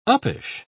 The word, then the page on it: uppish: British English